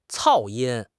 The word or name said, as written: 噪音